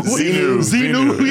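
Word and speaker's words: Xenu: Zenu.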